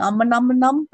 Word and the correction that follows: Om Nom: but I'm like, I know it's the wrong name. – Well, you're close. Om nom.